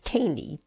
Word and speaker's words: Tainy: Now we're going to read a tribute from, I think it's pronounced Tainy, T-A-I-N-Y, who's a producer and songwriter.